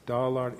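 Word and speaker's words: Dağlar: Just for those of you who don't know Turkish transliteration, the G with the little hat on it is silent. You don't pronounce it. So it's Dalar Ile Tash, the S with the squiggle, Tash Lar Ile.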